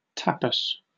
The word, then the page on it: tapas: British English